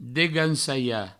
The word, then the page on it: degancalhar: Provençal; degansaia